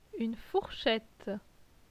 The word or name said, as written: Une fourchette